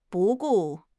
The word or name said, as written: 不顾